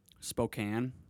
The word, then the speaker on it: Spokane: Or like Spokane, but it's spelled Spokane. – Is that how it is? – Mm-hmm. – Oh, okay. – S-P-O-K-A-N-E.